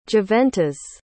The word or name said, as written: juventas